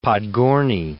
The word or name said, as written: Podgorny